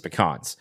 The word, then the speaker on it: pecans: For example, pecans, and that's how you say it. You say pecans. I don't care. I will die on this hill. It's pecans.